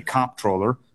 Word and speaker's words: comptroller: I happen to say comptroller. I hit it hard on the first syllable. And some people say controller.